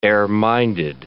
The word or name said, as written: air-minded